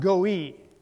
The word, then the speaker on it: goy: Now, the Hebrew word looks like goy, but it's pronounced goi. Goi is the pronunciation of that.